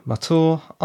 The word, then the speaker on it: Mathur: My name is Roy Mathur. I'm a writer. Mathur is spelt M-A-T-H-U-R.